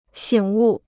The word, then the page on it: 醒悟: xǐng wù